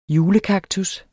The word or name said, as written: julekaktus